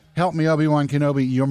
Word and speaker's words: Obi-Wan Kenobi: Help me, Obi-Wan Kenobi.